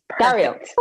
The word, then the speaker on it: Gariel: I'm probably not going to pronounce this correctly, so sorry for anyone who's listening and really knows, but the 1717 Gariel Stradivarius. Is it Gariel or Gariel? – Perfect. – Gariel.